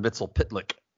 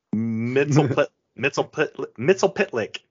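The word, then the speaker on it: Mxyzptlk: But Mr. fucking Mitzel Plixic Tick Tip, whatever, how you say his name? – Mr. Mitzel Pitlick. – Mitzel Pitlick.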